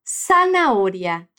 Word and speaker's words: zanahoria: A primeira delas é com o som de S, S, como na palavra zanahoria, que é cenoura em espanhol. Repete, zanahoria.